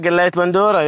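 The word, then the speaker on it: galayet bandora: You cooked galiat bandura, right? This is how you say it? – Ah, galiat bandura, yeah, right.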